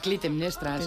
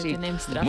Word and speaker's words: Clitemnestra: Clitemnestra. – Clitemnestra, sí. – Clitemnestra.